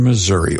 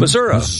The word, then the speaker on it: Missouri: Missouri. Yeah, I worked for a news director one time who insisted it was called Missouri. You know, you got to pronounce it like the locals.